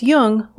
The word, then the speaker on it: Jung: Just be sure to pronounce Jung with a hard J. So with special thanks to Routledge, this is Laura London, and you've been listening to Speaking of Jung.